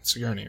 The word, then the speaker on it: Sigourney: Sigourney? Is it Sigourney? Sigourney, right? Hard G? – Yeah.